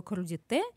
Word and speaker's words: крудите: Что такое крудите? а, ударение на последний, правильно? – Да, да, да, да правильно.